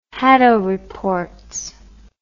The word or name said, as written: hadow reports